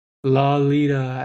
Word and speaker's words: Lolita: Oh, Lolita, as in lollipop. That's how it's pronounced.